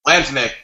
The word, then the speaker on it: Lantumic: You can find me anywhere at L-A-N-T-U-M-I-C. – Like how that. Do you pronounce that? Lantimic? – Lantimic. However you want to spell it.